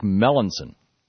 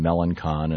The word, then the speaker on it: Melancon: It's French. He's from South Louisiana. It's more like Melanson. And I've been saying Melancon, and he's not.